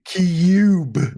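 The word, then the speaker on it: Cyube: It's spelled weird, too. It's like C-Y-U-B. – It's like Cube.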